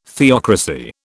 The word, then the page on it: theocracy: British English